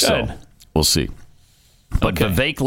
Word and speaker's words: Vivek: And they were told, Vivek like cake. – And so, nobody except them, and I've a few times when I venture out there on that limb, nobody pronounces it like that. Okay. Everybody says Vivek.